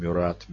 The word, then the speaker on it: Мюрат: Иоахим Мюрат. Мюра, вообще, такое правильно по-французски, без «т». Почему мы стали эти «т» приделывать? Потому что французскими буквами там «т» на конце, но оно не произносится.